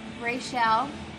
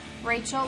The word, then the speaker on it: Rachelle: All right, so my entire life, my name, thank you, Mom and Dad, R-A-C-H-E-L-L-E. Yes, sounds like Rachel or Raquel, given the season. It is pronounced Rachel, like in Friends. Ross dates her.